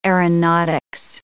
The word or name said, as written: aeronautics